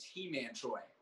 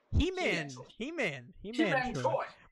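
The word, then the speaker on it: Ji-Man Choi: I hate to correct you, buddy. It's He-Man Choi. – He-Man Choi.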